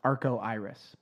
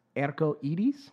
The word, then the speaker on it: Arco Iris: If you were just reading it, it would look like it's Arco Iris, but it's actually... It's pronounced Erco Edis.